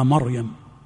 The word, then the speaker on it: مريم: يخطئ كثير من الناس فيفخم راء فرعون ويرقق راء مريم، يا مريم، ها، ويرقق راء مريم فيقول يا مريم، يا مريم اقنتي لربك. والصواب العكس فيهما، الصواب العكس فيهما.